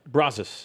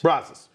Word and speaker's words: Brazos: Brazos. – Brazos.